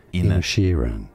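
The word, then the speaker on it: Inisherin: Okay, with a stress on that third she syllable. Inishirin. – No, it's Inishirin. Ed Sheeran.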